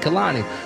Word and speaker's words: Kalani: People be getting on me because I be like, Kalani, Kalani. It's Kalani. That's how you say her name the correct way.